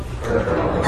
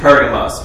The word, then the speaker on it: Pergamum: This says Pergamos, but it's Pergamum. Okay? All right, verse 12. And to the angel of the church in Pergamum write the words of him who has the two-edged sword.